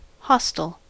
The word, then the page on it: hostel: American English